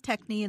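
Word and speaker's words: Techny: 4 years ago, I had three technical arborvitaes and then five hence midget arborvitaes, if I'm pronouncing those things right. – One is techni and the other is hetz midget.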